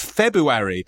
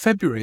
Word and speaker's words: February: No, I think I wrote P-A-C-K-E-R. – Oh, I see. Fair enough. Yeah. – But the thing with February is, because what happens is you think to yourself, you get so used to it being February, yeah, with the extra R. – It's not extra, it's just what it is. – Yeah, but with a kind of... – slightly silent R, I guess. – Yeah, silent R. – Not really. – A trouble-causing R. – A troublesome R.